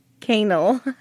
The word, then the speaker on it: Quesnel: Quesnel Forks? I'm sorry for our Canadian listeners. I apologize. – Canal. – It's not spelled like canal, but that's how you say it, so I apologize.